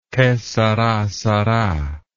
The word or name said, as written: che sara sara